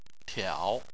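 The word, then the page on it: tiao: tone 3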